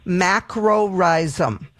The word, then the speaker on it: macrorhizum: It's Geranium macrorhizum. And I'm going to spell it. Macrorhizum is spelled MacroRhizum, macrorhizum.